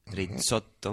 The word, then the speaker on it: Rizzotto: Para los que no me conocen, mi nombre se escribe con doble, mi apellido se escribe con doble Z y doble T, Risotto.